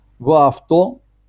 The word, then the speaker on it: ваавто: «Ваавто» – ударение на это последний слог.